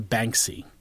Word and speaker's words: Banksy: I'm going to compare Jackass to Banksy. – Okay. – And here's why. Because, did I say his name right? – Yes.